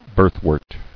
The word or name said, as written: Birthwort